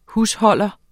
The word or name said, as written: HUSHOLDERNE